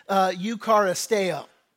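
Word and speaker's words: eucharisteo (εὐχαριστέω): In the Greek, it's pronounced eucharisteo. That's the word, eucharisteo.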